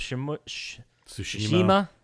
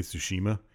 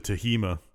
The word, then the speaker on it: Tsushima: Wait, did you see that the guy who was doing the awards that was announcing them, he couldn't say Tsushima? He kept saying it wrong? – I can't either, so I... – Like, Ghost of Tahima.